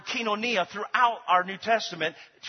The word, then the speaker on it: koinonia: The plan there is the Greek word koinonia or kinonia. Kinonia is how they say it in Greek.